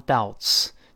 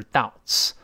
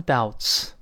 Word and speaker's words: doubt: 那这句话都可以背一背，叫 I have some doubts，就对某事有疑虑。那注意这个怀疑。这个名词 d o u b t，注意 b here is silent，那个字母 b 是不发音的，不要读 doubt，doubt 不对，那个 b 不发音，直接读成单数读成 doubt，doubt，复数加 s 就是 doubts。 I have some doubts，我有些疑虑。